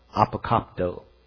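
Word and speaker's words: apokapto (ἀποκόπτω): Cut it off, apokapto. Let me pronounce it right, apokapto.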